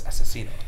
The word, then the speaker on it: Asesino: No, it's Asesino. It's two asses and then Eno.